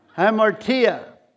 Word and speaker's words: hamartia: The Greek word for sin is hamartia. It's spelled like this way. H-A-M, like ham, and A-R-T, like art, and tia, I-A.